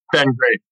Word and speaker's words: Ben Graeff: Ben Graeff.